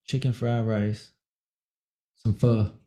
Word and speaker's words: pho: Where I'm staying, it's called, you know, because it's pho, but it's pronounced pho.